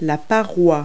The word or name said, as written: rock face